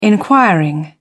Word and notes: inquiring: British English

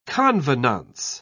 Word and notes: convenance: British English

